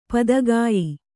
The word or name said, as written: ಪದಗೆಯ್